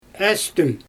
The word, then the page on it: Astüm: Xyzyl